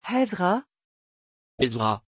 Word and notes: ezra: French

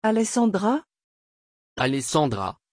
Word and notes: Alessandra: French

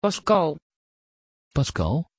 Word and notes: pascal: Dutch

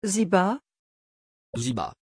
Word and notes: Ziba: French

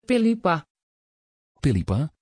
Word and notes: phillipa: Dutch

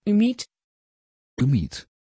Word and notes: ümit: Dutch

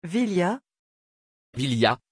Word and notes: vilja: French

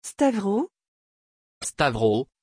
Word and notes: Stavros: French

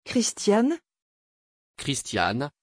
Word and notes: christiane: French

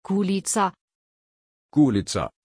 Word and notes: gulizar: German

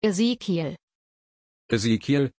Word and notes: ezékiel: German

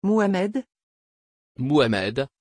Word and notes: Muhammed: French

